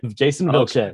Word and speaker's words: Jason Vilches: Jason Vilches.